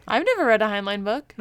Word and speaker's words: Heinlein: So this one, I guess we could always just say, it is Revolt in 2100 by Robert Heinlein.